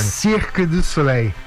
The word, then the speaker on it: Cirque du Soleil: Cirque du Soleil. – Ah, eu falei certo. – Cirque du Soleil.